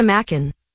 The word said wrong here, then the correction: Macan: That is not how you say it. – What's the word? – Macan? – It's a Macan.